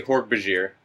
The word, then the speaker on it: Hork-Bajir: Now, how do you pronounce, is it Hork-Bajir, Hork-Bazar? – I say Hork-Bajir.